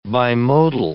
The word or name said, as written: bimodal